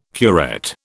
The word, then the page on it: curette: British English